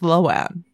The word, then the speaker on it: Lohan: And it she just says it, and then she said in an interview, it's like, yeah, everyone's always pronounced it wrong. It's Lohan.